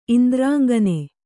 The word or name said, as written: ಇಂದ್ರಾಂಗನೆ